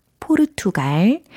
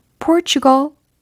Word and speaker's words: Portugal: 철자는 P-O-R-T-U-G-A-L 이라는 철자입니다. 과연 어떻게 발음하면 좋을까요? 포르투갈은 영어 발음으로 Portugal, Portugal. – 네, 이와 같이 하시면 되겠습니다. Portugal, Portugal. 아하, 포르투갈하고는 확실히 차이가 있네요. 그죠?